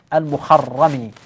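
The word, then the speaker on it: المُخَرِّمي: من الأمثلة التطبيقية على اتفاق الاسم الأول والثاني مع اختلاف النسبة، راو يقال له محمد ابن عبد الله المخرمي، كيف نطقتها أنا؟ بضم الميم وفتح الخاء وتشديد الراء،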